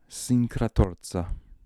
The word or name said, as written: Sincratorza